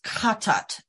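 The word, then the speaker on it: chatat (חטאת): I think it's pronounced chatat, actually, with the emphasis on the first syllable.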